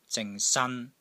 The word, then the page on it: ZING3 SAN1: Cantonese